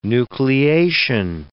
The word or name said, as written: nucleation